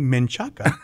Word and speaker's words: Menchaca: It's really Menchaca because it's spelled with an E, so it's Menchaca, okay?